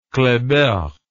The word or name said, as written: kleber